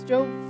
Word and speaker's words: Job: Scriptures that kind of just touched my heart right now, but and I don't know, do you pronounce it as Job? Is it Job? Yeah, okay, that's what I thought.